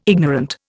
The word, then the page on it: ignorant: Indian